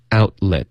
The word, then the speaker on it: outlet: and checked every outlet， outlet， out 的特省略， let 的特也省略， outlet， outlet， checked every outlet。